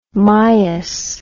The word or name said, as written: mias